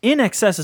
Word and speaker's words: INXS: And it's NXS is how it's pronounced, right? – Right, NXS. – But it is spelled INXS, one word, all caps.